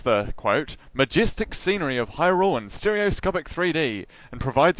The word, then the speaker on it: Hyrule: is like Hyrule, that's how I've always said it, but is that way off? – Yeah, Hyrule.